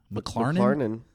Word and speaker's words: McLarnon: I don't think that's how you say his last name. – McLarnon. – No, that's it. – I've been saying it wrong.